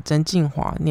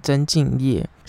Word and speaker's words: 曾敬驊: 上集最后介绍了不良执念清除师，但是我把曾静华念成曾静业。